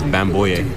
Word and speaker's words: Bamboye: Luke, and then it's Bamboye. – Bamboye. – Yeah.